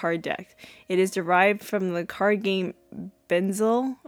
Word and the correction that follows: Bezique: I don't know how to say it. It's Bezique.